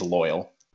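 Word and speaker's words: Loyle: And then do a little singing, some nice melodies, and then he'll get like this guy, Loyal, L-O-Y-L-E. I think it's Loyal. – Loyal. – Like Loyal. – I would like to hear someone from Louisiana pronounce that name. – Loyal. It just sounds like that.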